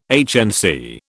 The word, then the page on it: HNC: British English